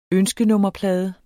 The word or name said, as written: ønskenummerplade